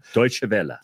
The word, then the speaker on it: Deutsche Welle: So I got an email inviting me to, I'm going to say it wrong because I'm not German, but Deutsche Welle. – Deutsche Welle. – Thank you very much.